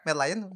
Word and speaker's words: Merlion: Merlion.